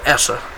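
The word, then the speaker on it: Esa: It might be Essa, because he's probably Swedish or Finnish. – I think he's from Finland. – Okay, so Essa. That's how you would pronounce that.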